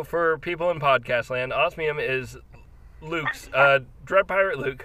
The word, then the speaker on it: Osmium: So, how is it pronounced? Osmium or Ossium? – Osmium.